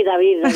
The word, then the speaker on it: David: Sí, David. En hebreo se pronuncia igual que en castellano.